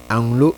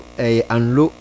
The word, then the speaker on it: Anlo: Anglo anlo as in anloga anlo afyan deny ga senior high school.